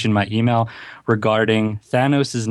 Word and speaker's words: Thanos: All because of Thanos. Thanos.